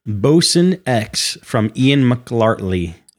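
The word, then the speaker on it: Boson X: It's supposed to be like Higgs boson, Bosun X. I got to say it like that.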